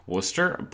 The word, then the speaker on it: Worcester: I'd just like to clarify, I know that it's pronounced Worcester,